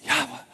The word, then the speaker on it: Yahweh: It's spelled YHWH. It's, you can't really say it. It's Yahweh. Because YHWH are no breathe words. They're not consonants. That's why we've made it consonants, like Yahweh.